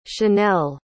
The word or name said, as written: chanel